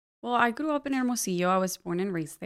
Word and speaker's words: Hermosillo: Hermosillo. – Mm-hmm. – Am I pronouncing it correctly? – Hermosillo, yes.